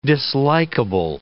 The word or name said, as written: dislikable